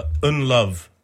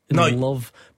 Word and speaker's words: Ndlovu: Peter Ndlovu. And who did you say? – and love. But see, this is the thing, because I'll apologise to the producer, okay, right? Because he had written an N-D-L-O-V. But I thought there was a U in the end, so I looked it up and there is a U on the end. So you're telling me it's some sort of silent U.